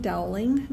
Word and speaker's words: Dowling: Linda Erlinson Dowling. My last name is spelled D-O-W-L-I-N-G.